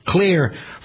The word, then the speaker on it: Xlear: Clear, for the purposes of this product and for the promotional purposes, is spelled X-L-E-A-R because it wants to separate, obviously, Clear from the rest of what we understand to be Clear. The reason they started with an X is because it contains xylitol.